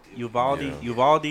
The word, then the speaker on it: Uvalde: Udall? What is it? How you say it? Udaldi? Uvalde, yeah.